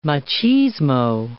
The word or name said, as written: machismo